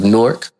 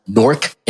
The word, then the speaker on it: Newark: So years ago, maybe about 2013, I developed a show, a program here in, you know, our great city of Newark, or Newark, as some people like to pronounce Newark, N-O-R-K, not N-E-W-A-R-K, but that's cool.